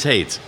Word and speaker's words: Haight: It is Haight, right? – Opposite of love, just like in San Francisco. – Like Haight Ashbury, right? Yeah. It's the same spelling.